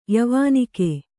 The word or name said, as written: ಯವಾನಿಕೆ